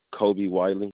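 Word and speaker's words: Kolby Wiley: Kobe Wally. – Kirby Wally. – Kobe Wiley.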